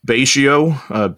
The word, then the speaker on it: Betio: And how do you say it? Betio? – Betio, B-E-T-I-O.